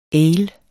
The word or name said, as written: ale